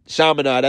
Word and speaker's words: Chaminade: The number 4 team in the Maui Jim Maui Invitational, the same Invitational that saw Virginia Tech defeat Michigan State, defeated Chaminade, that's how you say it, 93-63.